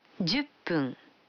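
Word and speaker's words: Дюппун (じゅっぷん): Вы заметили, что когда фун добавляется к 10, дю, то произносится пун. – Послушайте и повторите каждую фразу. 5 минут. – Гофун. – 10 минут. – Дюппун.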